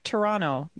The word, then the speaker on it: Toronto: But you have to pronounce it like Toronto. There's no T at the end.